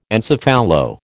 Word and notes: encephalo: American English

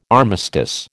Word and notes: armistice: American English

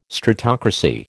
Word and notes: stratocracy: American English

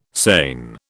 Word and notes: sane: British English

